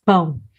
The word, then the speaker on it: pão: And this word is not the easiest to pronounce for all non-native speakers. And I was reading a sentence with the word pão, and I forgot to make the very necessary nasal sound.